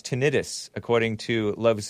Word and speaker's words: tinnitus: Love Zoda does not like juice says it is pronounced tinnitus, not tinnitus. Itis means inflammation, which has nothing to do with ringing of the ear syndrome.